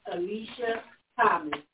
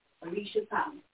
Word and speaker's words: Alesia Thomas: Please say her name, y'all, and I want to say it right. It's Alicia Thomas. – And that was A-L-E-S-I-A-T-H-O-M-A-S. Alicia Thomas.